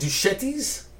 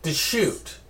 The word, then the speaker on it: Deschutes: How do you say it? Is that Duchetti's? – Deschutes.